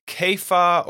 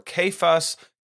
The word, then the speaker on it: Cephas: In Aramaic, Peter is translated Cephas or Kepha or Kephas. Kefa,